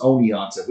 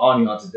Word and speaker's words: Oneonta: Yes, yeah, Except up there, they pronounce it as Oneonta. – We call it Oneonta down here.